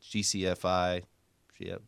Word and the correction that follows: GFCI: Did I say that right? yeah. GFCI. Uh, there you go.